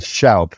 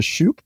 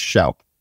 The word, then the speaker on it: Shoup: It's Shoup. Everybody says Shoup, but it's Shoup.